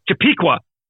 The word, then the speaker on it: Chappaqua: I, I, I, I had the pronunciation of that fucking town in my head earlier. Chappaqua. There it is.